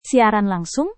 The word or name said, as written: siaran langsung